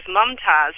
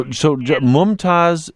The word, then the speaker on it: Mumtaz: I think it's a boy's Arabic name, and it's spelled J-A-M-I-L, but I spell it J-A-M-E-E-L. My first name, though, is Mumtaz, M-U-M-T-A-Z.